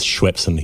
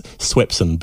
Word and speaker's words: Swepson: I don't know why I keep pronouncing him as Swepson. He's Swepson, but somehow the Schwepp of essence of our childhoods has just got into my head.